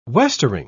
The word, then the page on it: westering: British English